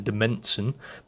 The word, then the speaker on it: demesne: And finally, the royal demesne is sort of spelt weird, as you can see, D-E-M-E-S-N-E, the demesne, but actually it's pronounced royal demesne, and that's the land, the area that was directly owned by the king.